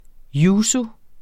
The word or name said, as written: yuzu